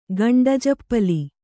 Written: ಗಂಡಜಪ್ಪಲಿ

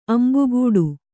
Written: ಅಂಬುಗೂಡು

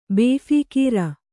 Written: ಬೇಫೀಕೀರ

